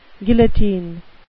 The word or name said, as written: guillotine